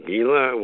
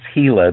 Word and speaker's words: Gila: Well, I understand your next Vision Quest is in the Gila Wilderness. I don't know if I'm pronouncing it right, or New Mexico. – No, it's pronounced Gila. The G is Gila. – Okay. Like an H. Gila.